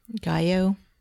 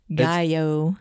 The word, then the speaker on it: gallo: Gallo. – Pico de gallo. It's that double L. Gallo.